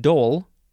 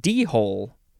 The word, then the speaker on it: dhole: The next word is, uh, well, it's pronounced dole, but it looks like d-hole. It's spelled D-H-O-L-E, dole.